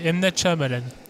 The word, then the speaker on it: Shyamalan: Le, Y est muet, M Malan.